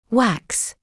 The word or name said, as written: wax